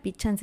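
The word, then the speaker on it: Pixán: Recuerden que pueden ordenar barf preparada en las redes sociales de Pichán Comida Ancestral. Pichán se escribe con X.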